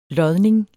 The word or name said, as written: lodning